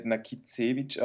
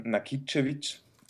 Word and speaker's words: Nakicevic: Genau, also mein Name spricht man nicht Nakicevic aus, sondern Nakicevic.